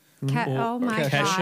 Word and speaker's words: Kesha: So take like Keisha. – Kesha, God.